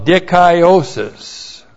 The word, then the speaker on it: dikaiosis: And then with the accusative of dikaiosis. That's D-I-K-A-I long O-S-I-S. Dikaiosis.